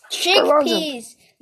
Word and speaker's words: chickpea: Chicken peas. – Chickpeas. There's no E-N, just chick.